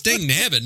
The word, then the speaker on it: dang nabbit: Gang nubit. – Gang nubit. – You didn't even say it right. Jesus. It's Dang Nabbit, not Gang Nubbit.